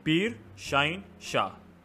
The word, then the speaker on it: Peer Shaheen Shah: So my name is pronounced Peer Shaheen Shah.